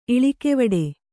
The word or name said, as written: ಇಳಿಕೆವಡೆ